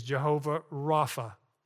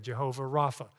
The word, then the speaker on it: Jehovah Rapha: So the second name is Jehovah Rapha, and that's spelled R-A-P-H-A, Jehovah Rapha.